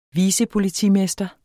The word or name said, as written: vicepolitimester